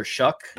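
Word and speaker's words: Shough: Tyler Shuck, that's how I learned that's how you pronounce his last name, even though it's S-H-O-U-G-H. It's Tyler Shuck.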